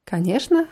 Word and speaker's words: Конечно: Конечно wird mit CNO am Ende geschrieben, aber diese Kombination C und N wird wie Sch ausgesprochen. Конечно.